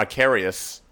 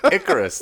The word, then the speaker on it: Icarus: Yeah, you're pronouncing it right. – Yeah. Or Icarus. – That's what I thought it was. – Icarus, the guy who flew too close to the sun.